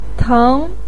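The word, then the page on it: teng: tone 2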